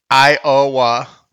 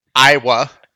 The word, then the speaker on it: Iowa: How many different ways can you pronounce Iowa? How many syllables? – Iowa. – Three. That's one. – Iowa.